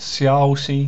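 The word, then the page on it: xiaoxi: xia1oxi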